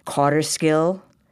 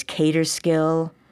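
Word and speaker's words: Kauterskill: Then it's Cotterskill with K-A-U-T-E-R. There's Caterskill. You'll hear all kinds of different pronunciations. There are some who are absolutely set that it's Cotterskill. There are those who've always said Caterskill or Catterskill.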